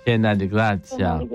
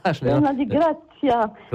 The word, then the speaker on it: piena di grazia: Ave Maria, piena de grazia.